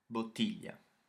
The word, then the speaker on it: bottiglia: Then we've got bottiglia, which means bottle, spelt B-O-T-T-I-G-A, bottiglia. – Yeah, bo-ti-gli-a, bottiglia.